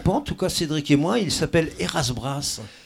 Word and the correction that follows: Ezra Brass: Ezra Brass, ouais, Ezra Brass. – J'ai mal prononcé, pardon.